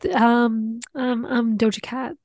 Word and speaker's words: Doja Cat: I'm going to go with Doja Cat.